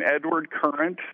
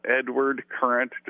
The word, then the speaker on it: Edward Current: Um, YouTube, I'm Edward Current. Uh, Edward Current, just spelled like it sounds.